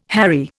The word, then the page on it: harry: Indian